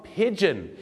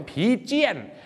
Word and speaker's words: pigeon: ออกเสียงว่า pigeon ไม่ใช่ผีเจี้ยนนะฮะบางคนออกเสียงผีเจี้ยนไม่ต้องเจี้ยนนะฮะ pigeon ครับ